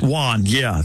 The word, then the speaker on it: yuan: In China, it's the yuan, the yuan, the Y-U-A-N. – Okay. – What? How do you say that? Yuan? – Not important. – Like a yawn? – Yuan, I'd bet. – Yuan, yeah. Thank you for that.